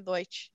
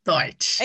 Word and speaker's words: Deutsch: Deutsch. – Deutsch.